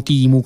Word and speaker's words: Temu: questa app si chiama Temu, Timu come la pronunciano, è un'app di acquisti.